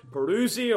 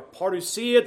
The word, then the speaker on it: parousia: It's the word, you may have heard the word, parousia or parousia, depending how you emphasize the syllables, but parousia or parousia.